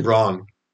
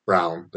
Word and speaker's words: Christian Braun: Christian Braun. Brown. They say he's Brown. I don't know why it's pronounced Brown, but Christian Brown at 2,600 is another guy that piqued my interest.